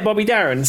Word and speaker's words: Darin: I don't know if I'm saying his name right, but I do know the song. It's Mac the Knife, but it's Bobby Darin. Darin, is that right? – Yeah, Bobby Darin.